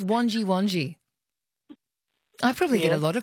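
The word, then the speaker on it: Wangi Wangi: One last night, Wonji Wonji. – Wonji Wonji. Oh, did I mispronounce that? – Last night. – Did I? Last night, did I not say Wonji Wonji? Did I say Wongi Wongi? – Yeah.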